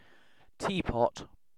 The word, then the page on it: teapot: British English